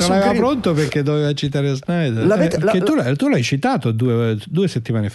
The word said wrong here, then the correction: Schneier: No, Schneier.